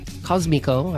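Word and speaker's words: Cósmico: I played the track Low Sun from their 2024 album Sonido Cosmico. Cosmico, I should probably pronounce it that way, released on Easy Eye Sound Records.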